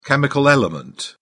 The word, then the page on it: chemical element: British English